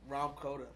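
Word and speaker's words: Rahm Kota: How do you say his name? – Rom Koda.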